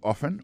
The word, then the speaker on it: often: Often times, nan, c'est O-F-T-E-N. Times, T-I-M-E-S, alors c'est comme ça qu'on dit ça. Soit on dit often, often, non, c'est O-F-T-E-N, malgré l'écrit often, on prononce often. Alors, je crois que T-A-B-B.